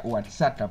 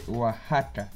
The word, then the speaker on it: Oaxaca: Yo de niño le decía Oaxaca porque se escribe con X. Tengan cuidado extranjeros, se dice Oaxaca, no Oaxaca.